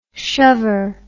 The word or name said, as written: shover